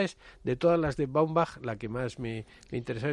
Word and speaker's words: Baumbach: Baumbach, como se, totalmente como se pronuncia, ¿no?